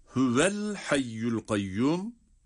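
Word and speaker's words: hüvel hayyul kayyum (هو الحي القيوم): Burada hüveye hareke verince yani vav harfi harekeli okununca sonraki el hayyul kayyum dediğimiz yerdeki El hayyu kelimesinin başındaki harekeli olan hemze okunmadan düşer. Yani telaffuzdan düşer. O zaman hüvel hayyul kayyum şeklinde okunur. Buradaki hemzeye vasıl hemzesi diyoruz.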